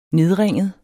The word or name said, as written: nedringet